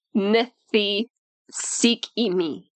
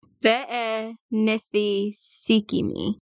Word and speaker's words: Benthesicyme: That pronunciation is how you say it. Ve-e-nithi-sik-i-mi. – Ve-e-nithi-sik-i-mi. – Yes. But it's spelled with a B. Even though it's pronounced with a V.